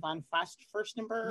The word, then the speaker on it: von Furstenberg: This is how do you pronounce her name? Diane von Furstenberg.